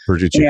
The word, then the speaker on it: Verducci: Yeah, Verducci.